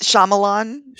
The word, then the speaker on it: Shyamalan: I'm sorry. Neither one of us can say his name. – Well, no, it's Shyamalan. – Shyamalan.